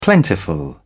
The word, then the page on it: plentiful: British English